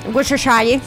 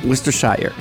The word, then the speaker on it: Worcestershire: Worcestershire. – C'est ça. – Worcestershire.